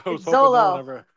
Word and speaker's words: Xolo: How do you say that dude's name? XOLO. – Zolo. – Zolo. – Say it with a Z.